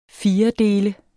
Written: FIRDELTE

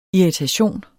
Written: irritation

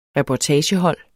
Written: reportagehold

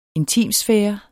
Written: INTIMT

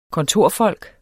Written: kontorfolk

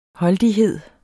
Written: holdighed

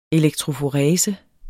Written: elektroforese

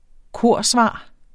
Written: korsvar

